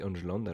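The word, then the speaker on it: Engelland: Euh, je pense que c'est ça la prononciation parce que Benoît euh, dans le dernier podcast nous a, dit Angeland. Alors je pense que c'est ça parce qu'il est québécois, il parle mieux anglais que nous.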